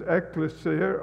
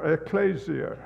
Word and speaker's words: ekklesia: The Greek word, and remember that the New Testament was written in Greek, the Greek word that is used here is ekklesia or ekklesia.